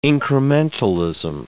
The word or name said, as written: incrementalism